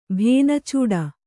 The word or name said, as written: ಭೇನಚೂಡ